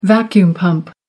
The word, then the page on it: vacuum pump: American English